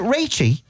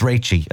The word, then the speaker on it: Rachie: It's not Rachie, it's Rachie, as in Rachel.